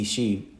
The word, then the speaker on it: Ishi: This ending with the I pronounced as a double E, Ishi, means my husband or my man.